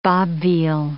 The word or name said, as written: bob veal